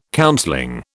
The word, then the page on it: counseling: British English